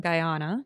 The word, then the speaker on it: Guyana: She's originally from Guyana.